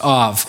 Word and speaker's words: av: It's spelled A-B, but it's pronounced av,